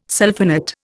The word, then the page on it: sulphonate: Indian